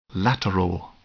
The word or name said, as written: lateral